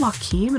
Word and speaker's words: Joaquin: And nobody corrected me for years because I always say, oh, Jonah Quinn Phoenix, Jonah Quinn Phoenix. It took years for people to be like, do you mean Joaquin?